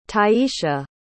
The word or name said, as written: tyesha